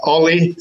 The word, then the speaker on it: Olli: Olli, spelled O-double-L-I, last name Harder, Olli Harder.